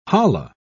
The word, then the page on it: holla: British English